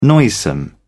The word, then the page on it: noisome: British English